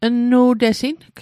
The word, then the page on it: nnu'tesing: Mi'gmaq